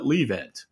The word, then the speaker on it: Livent: But if you're not going to change the name, at least pronounce it properly and call it Livent. – I mean, even if they don't pronounce it Livent, I think a lot of other people will. – Because that's the way lithium, L-I, is pronounced in most of the world that has a major impact on the lithium markets.